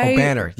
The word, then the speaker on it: Obanor: O-B-A-N-O-R. – O-B-A-N-O-R? – Mm-hmm. – Oh, that's O'Banner. – Close. O'Banner. – O'Banner, yes.